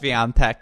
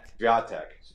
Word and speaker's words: Swiatek: Yes, Swiatek. – Swiatek.